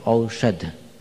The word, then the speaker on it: औषध: शुरू में अगर औ लगा रहे हो तो आखिरी में ध रहेगा, औषध। लेकिन लोग बहुत अच्छे उच्चारण के साथ औषधि ले ली आपने, ऐसे करके बात करते हैं। औषधि, औषधि नहीं होता, औषध होता है, और औषधि ये शब्द होता है।